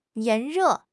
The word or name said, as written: 炎热